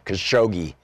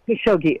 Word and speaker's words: Khashoggi: Is it Khashoggi? – Because it's a hard G. That's how Bob Ney pronounced it. He used to live in Saudi Arabia, so I'm going to go with his pronunciation, Khashoggi.